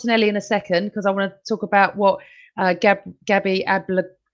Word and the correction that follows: Agbonlahor: I can't say his name, Agbonlahor said.